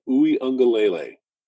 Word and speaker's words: Uiagalelei: He's out with COVID, and I'm going to butcher this name, DJ Uiagalelei.